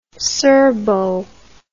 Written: serbo

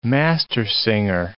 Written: mastersinger

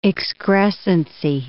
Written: excrescency

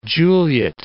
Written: Juliet

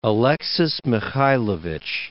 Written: Alexis I Mikhaylovich

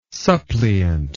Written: suppliant

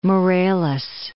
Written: Morelos

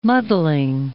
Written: muddling

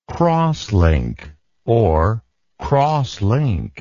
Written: crosslinkable

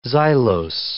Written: xylose

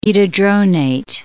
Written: etidronate

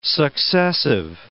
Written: successive

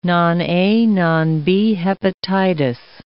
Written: non-A, non-B hepatitis

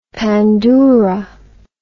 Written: pandoura

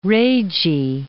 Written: Rhagae

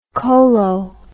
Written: cholo

